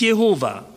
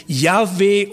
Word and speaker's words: Yahweh: Ich will nur mal so sagen, das Wort heißt nicht Jehova. Das Wort wird vermutlich ausgesprochen Yahweh oder Yahweh.